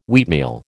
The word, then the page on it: wheatmeal: American English